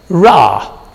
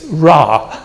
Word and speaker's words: Ra: Now, you may have heard of the Egyptian god of the sun, Ra, which is spelled R-E, which doesn't make any sense to me.